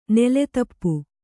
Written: ನೆಲೆದೀಪ

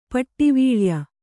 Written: ಪಟ್ಟಿವೀಳ್ಯ

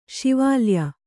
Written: ಶಿವಾಲಯ

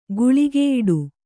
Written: ಗುಳಿಗೆಯಿಡು

